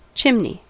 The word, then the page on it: chimney: American English